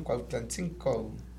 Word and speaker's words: Cuautlancingo: Así era, ¿verdad? Siempre era la pronunciación, Cuautlancingo.